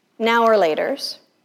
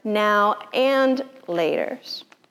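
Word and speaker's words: Now and Later: I discovered this week that these candies are not called now or laters. It's not just we were saying it wrong, all squished together, now or laters. They're not even now or laters. They're now and laters.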